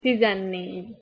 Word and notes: tizennegy: Hungarian

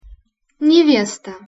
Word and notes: nevesta: Russian

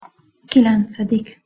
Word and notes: kilencedik: Hungarian

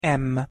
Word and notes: m: Hungarian

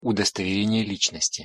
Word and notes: udostoverenie licnosti: Russian

